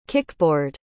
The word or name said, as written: kickboard